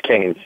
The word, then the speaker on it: Keynes: It's Keynes.